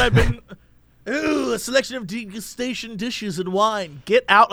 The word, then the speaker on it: degustation: I just Merriam-Webster it, and it's degustation. That's the actual pronunciation.